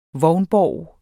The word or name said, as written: vognborg